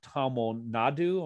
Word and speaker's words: Tamil Nadu: Blueskin Gods takes place in the state of Tamil Nadu. Am I pronouncing that correctly? – Mm-hmm.